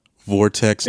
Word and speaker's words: Vortex: Vortex yeah.